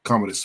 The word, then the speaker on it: Commodus: Number 33, Commandos from Gladiator. Uh, uh, uh, uh if I said it right. The, the Oh, Commodus. Commodus from Gladiator.